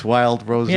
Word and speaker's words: Rosé: It's Wild Rosé. – Yeah. I mean, there's an accent on the E, but Wild Rose, Wild Rosé.